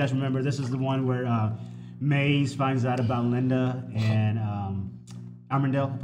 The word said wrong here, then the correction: Amenadiel: hopefully I said his name right. – Amenadiel.